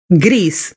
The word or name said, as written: gris